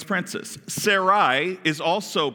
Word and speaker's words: Sarai: Then God said to Abraham, regarding your Sarah, your wife, her name will no longer be Sarai, is actually how it's pronounced, will no longer be Sarai.